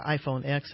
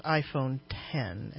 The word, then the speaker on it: iPhone X: So last hour we talked about the iPhone X, and according to an article here, it's pronounced iPhone 10, as X as in 10 in the Roman numeral 10.